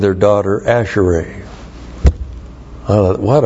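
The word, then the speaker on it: Asherai (אַשְׁרֵי): Asherai. A-S-H. Shava, R, long E, Asherai.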